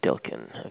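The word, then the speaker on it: Dylkin: So it's Dean DeLucan. – How do you spell the last name? I don't want to misspell that and offend him. – I understand. It's D-Y-L-K-I-N. – Dilkin. Okay, and then Dean, D-E-A-N. Okay. – Correct.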